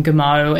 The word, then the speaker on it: Gameau: And Damon Gamow, it's spelled Gameau, his surname,